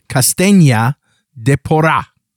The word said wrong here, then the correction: Castanha do Pará: Do Pará. – Do Pará. – Yeah, that's it. – Castanha do Pará.